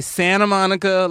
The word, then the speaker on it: Santa Monica: And it's we all have agreed to not pronounce the T. It takes too much time. – Sure. – We're not fops. We're not assholes. We say Santa Monica like a normal American.